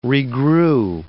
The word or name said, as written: regrew